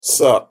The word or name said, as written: sup